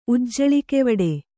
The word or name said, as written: ಉಜ್ಜಳಿಕೆವಡೆ